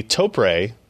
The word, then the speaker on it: Topre: First up, Sebastian wrote a review of the Topre, which I believe this is the correct pronunciation, right, Ken? We figured it out after years of me having one of these keyboards on my desks.